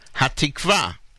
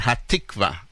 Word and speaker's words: Hatikva: Hatikva is the way it should be pronounced, not Hatikva. Right? Where do you emphasize the syllable or syllable?